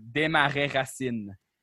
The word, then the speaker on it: Desmarais Racine: So with sometimes French word, you just got to say it like you say it in French. So it's Desmarais Racine.